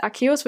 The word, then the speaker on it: Arceus: Also er schreibt, Arceus wird richtig Arceus ausgesprochen.